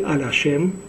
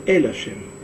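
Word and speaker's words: эль ашем (אל השם): Что значит ватитпалель аль ашем? Если правильно говорить на иврите, то нужно говорить эль ашем. Алеф ламет. Эль ашем.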